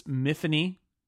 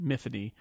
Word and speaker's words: Myfanwy: So, we should say, the main character of The Rook is Miffany. Rhymes with Tiffany. They say that. Even though it's spelled M-Y-F-A-N-W-Y, it's Welsh spelling. She pronounces it Miffany.